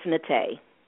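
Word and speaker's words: Naté: So what is the proper pronunciation? – It's Nate. – Nate. There you go.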